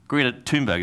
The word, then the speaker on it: Greta Thunberg: Greta Thunberg is how you say her name, right? Yeah.